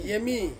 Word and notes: emi: Ansus

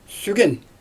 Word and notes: sygen: Xyzyl

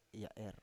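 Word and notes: ya’er: Biak Utara

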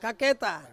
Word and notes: kaketa: Ansus

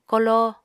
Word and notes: koló: Apatani

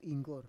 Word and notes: inggor: Roon

